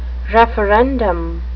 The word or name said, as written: referendum